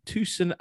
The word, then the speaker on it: Tucson: And Tree Speak, apparently, are from Tucson, Arizona. Have I said that right? Yeah. Tucson. You wouldn't... Tucson, yeah, you wouldn't think they're an Arizona band.